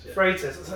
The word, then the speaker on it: Freitas: Freitas. – I should have asked you how to pronounce it before we started. It's Portuguese. Everyone struggles with it.